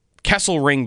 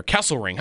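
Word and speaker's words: Kesselring: How good does this player, Kessel Ring, by the way, is how you pronounce his name. The L comes before the R. Kessel Ring.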